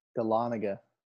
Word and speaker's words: Dahlonega: Is it Dahlonega? – Dahlonega. – Dahlonega, okay.